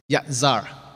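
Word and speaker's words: Yatzar (יצר): The word form in Hebrew is yatzar.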